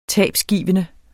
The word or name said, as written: tabgivende